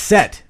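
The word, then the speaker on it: Cett: If you listen to the books, they pronounce it Set.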